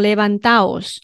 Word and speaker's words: levantaos: 好嘞，变成 levantaos，我们念作 levantaos。那这边注意一下，不需要标重音符号，因为它原本 levantad 重音就在这个这里。